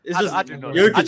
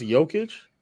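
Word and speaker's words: Jokić: How long will it take them to realize Jay and Jokic is silent? I forget. – So you forget that it's pronounced Jokic? – I say Jokic and Jokic sometimes.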